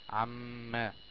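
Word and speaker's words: عمّ: عما زي كلمة إن، دي عما الميم هنا مشددة فتسمى حرف غنة مشدد، وحكمها انها تغن بمقدار حركة. تنطق ازاي؟ عما.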